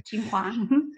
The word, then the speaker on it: Tsinghua: Well, you have your BE degree from Tsinghua. Did I say that right? Okay. – Tsinghua.